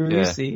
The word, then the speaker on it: Rousey: Rousey? – Yeah, Rousey.